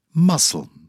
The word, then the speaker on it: muscle: C. Indictment. Muscle. Silent C.